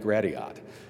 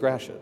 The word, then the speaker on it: Gratiot: Back when Sirius XM first launched, they had the local traffic, and they kept always calling it Gratiot. Clearly, that's a guy or gal in Washington, D.C. that has no idea that in Detroit it's pronounced Gratiot.